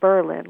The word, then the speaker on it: Berlin: You know they pronounce it Berlin, like rhymes with Merlin.